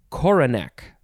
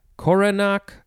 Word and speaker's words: coronach: It is coronach. Coronach, coronach. How do you say this word? I think it's coronach. C-O-R-O. N-A-C-H.